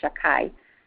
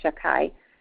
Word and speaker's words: Shakai: Shaddai, and if we don't want to say the name of Hashem, we pronounce it Shakai,